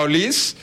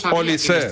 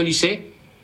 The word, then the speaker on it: Olise: Oye, el otro día tuve una agria polémica con los compañeros porque tú me garantizaste que se pronuncia Olis y me pusieron cortes del propio jugador que dijo que es Olise. – No, efectivamente se dice Olise,